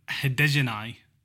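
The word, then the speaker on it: Hadergjonaj: Florent Hedegenai. – Yes. – Am I right? – Yeah, good pronunciation there.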